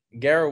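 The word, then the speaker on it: Garret: But yeah, so Garrett one T, two R's, one T.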